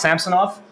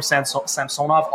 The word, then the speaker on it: Samsonov: It's pronounced Samsonov based on what he said in the video the Leafs posted. So I've been calling him Samsonov all summer.